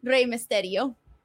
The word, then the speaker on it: Rey Mysterio: Yeah, like Rey Mysterio. Okay. So you gotta roll your R's.